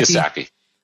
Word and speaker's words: Kiyosaki: Kiyosaki.